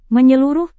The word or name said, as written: menyeluruh